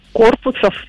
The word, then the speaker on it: корпусов: Дело в том, что у данного слова два значения, Елена. Туловище, ударение в котором падает на первый слог, и сооружение с акцентом на последний. И так как в данной новости, Елена, речь идет именно о строении, следовательно, правильно говорить «корпусов».